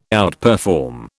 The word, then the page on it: outperform: British English